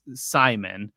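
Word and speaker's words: CIMON: In 2018, the ESA debuted the Crew Interactive Mobile Companion, known as Simon, which is spelled C-I-M-O-N, a round volleyball-like computer.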